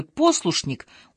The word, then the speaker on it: послушник: Ну, кто, кроме Довлатова, знал, что в слове «послушник» ударение падает на первый слог?